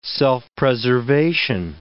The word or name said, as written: self-preservation